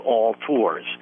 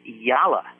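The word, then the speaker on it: Yalla: The company is not a Southern company. It is not All Tours. It is Yala, and there is a little bit of an Allah in Yala, because Yala is a Arabic word, and the meaning is very easy to translate.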